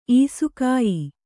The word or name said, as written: ಈಸುಕಾಯಿ